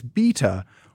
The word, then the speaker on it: Beta: I've always assumed it was Beta, but I've heard certain people pronounce it Beta, and a Brit even pronounced it as Beta. I won't speak to the Brits, but it is Beta Ray Bill. – It's Beta Ray Bill, like yeah. Alpha, Beta...